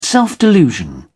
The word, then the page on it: self-delusion: British English